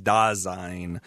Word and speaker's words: Dasein: First of all, it's the pronunciation is Dasein, and it's Heidegger's way of just talking about human existence in some ways.